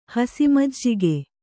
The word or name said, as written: ಹಸಿಮಜ್ಜಿಗೆ